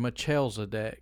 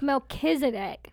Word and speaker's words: Melchizedek: Melchizedek. I even heard Melchizedek. – Nobody says that. – No, I heard it. I promise it. – Nobody says that. – Yeah. – It's Melchizedek.